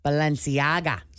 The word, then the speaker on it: Balenciaga: Balenciaga. – Is that how you pronounce it? – Mm-hmm. – Balenciaga? – You did great. Nice job.